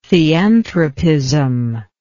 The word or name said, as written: theanthropism